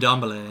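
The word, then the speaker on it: Ndombele: in Domble. Can I say that right? In Dom- in Dombele, yeah. – In Dombele. It was more what we said last week.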